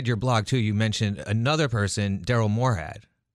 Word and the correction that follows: Morehart: And he, how do you say his last name? – Morehart.